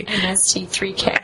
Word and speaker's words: MST3K: basically MSTK it, right? And before, because we were in the UK, we didn't even know what MSTK was. I can't even say it properly. – MST3K.